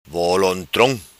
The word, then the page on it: Volontrom: patua